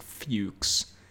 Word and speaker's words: Fuchs: I'm going to assume it's pronounced Fuchs. But it is spelled F-U-C-H-S. It's definitely Jason Fuchs.